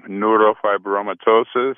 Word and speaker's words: neurofibromatosis: I have a disease, and I'm probably pronouncing it wrong, neurofibromatosis. – No, you nailed it. That's right.